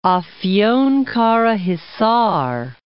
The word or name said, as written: Afyonkarahisar